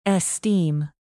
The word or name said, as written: esteem